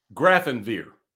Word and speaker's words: Grafenwehr: It's, it's not pronounced any way like it's spelled, and it's got a W in it. It's not Wiesbaden. It is. Grafenwehr. That's it. Grafenwehr.